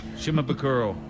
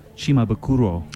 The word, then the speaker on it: Shimabukuro: Shimabukuro. – Has a much better pronunciation of Jake's name. – Shimabukuro.